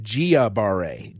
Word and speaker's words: Guillain-Barré: And a nice lady called in and said, "Hey, dummy, it's pronounced Guillain-Barré, Guillain-Barré."